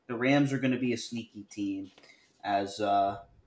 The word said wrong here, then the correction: Puka Nacua: Puka Nacua.